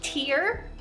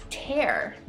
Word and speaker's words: tear: Twenty tiene dos pronunciaciones. is tear, is una lágrima, o tienes tear, y significa romper algo en pedazos.